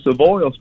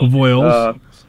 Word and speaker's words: Avoyelles: And the parish that you talked about is Avoyelles. You were close, Avoyelles Parish. – Avoyelles.